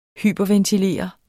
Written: hyperventilere

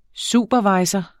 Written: superviser

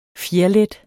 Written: fjerlet

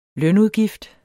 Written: lønudgift

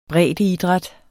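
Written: breddeidræt

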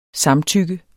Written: samtykke

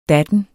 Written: datten

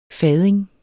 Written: fading

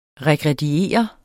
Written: regrediere

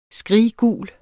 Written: skriggul

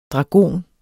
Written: dragon

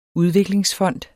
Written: udviklingsfond

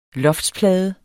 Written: loftsplade

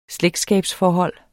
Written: slægtskabsforhold

